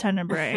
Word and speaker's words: Tenebrae: uh, but this week, yeah, we did Tenebrae or Tenebrae as um, the Americans like to say it. – It's pronounced Tenebrae.